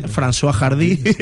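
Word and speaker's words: Françoise Hardy: Yo puedo decir el cantante, François Hardy. ¿No? ¿Lo he dicho bien? – Sí, perfectamente.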